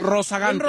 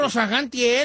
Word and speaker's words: rozagante: Ramos lo agarró chiquinquiel, andaba bien regoceante, ¿cómo se dice? Rezagante él. – Rozagante. – Rozagante él, cuando estaba muy ahí, muy machito, que lo agarra chiquinquiera y bolas.